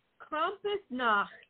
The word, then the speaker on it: Krampusnacht: Oh, so it's Krampusnacht. Just get in there with the Nacht.